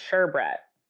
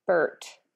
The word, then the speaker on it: sherbet: It's pronounced sherbet. – Sherberette. – It spells sherbet. But I think maybe... – Sherbert. – I say sherbert. Like, no, it's spelled sherbet, but we say sherbert.